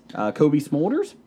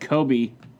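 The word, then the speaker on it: Cobie Smulders: Kobe Smulders, she will be there. – Yes. – I got that name right. – You did. No, yes. You said Kobe.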